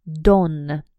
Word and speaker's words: dawn: Don. Don. Пишется D-A-W-N. Ну-ка, маленький диктантик. D-A-W-N.